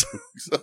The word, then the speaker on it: Boogz: B-O-O-G-Z. – Really? – Yes. – Okay. It's and not Boogs? – No, it's Boogs. It's Boogs.